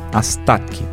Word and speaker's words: Astatke: Você ouviu Yegele Tezeta, do etíope Mulatu Astatke. Astatke, na verdade.